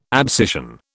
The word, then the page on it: abscission: British English